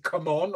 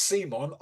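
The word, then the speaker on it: CMON: And then we had a bit of an argument of whether you pronounce it come on or Simon. It's Simon. I was right, by the way. So it's short for call mini or not, but you pronounce it Simon.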